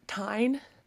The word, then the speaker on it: Tijn: Her younger brother is named Tyne and it's spelled T I J N.